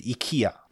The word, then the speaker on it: IKEA: 念错的哈，这个叫 IKEA，到底是念 IKEA 还是 IKEA？怎么念哦？瑞典人教我们来念的话呢，其实有各种版本哦，有人念 IKEA，有人念 IKEA，那到底应该怎么念？正确应该是按照瑞典发音类似 IKEA。不过重音是放在中间呐。